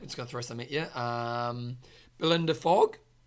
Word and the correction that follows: Fong: Fong. – Oh, I always say that wrong, don't I? Fong.